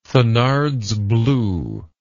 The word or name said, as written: thenards blue